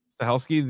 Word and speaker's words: Stahelski: This is just from the Wikipedia, but Chad Stahelski, how would you say it? – Stahelski, the director of all the John Wick movies, mm-hmm.